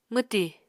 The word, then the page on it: mwti: Apatani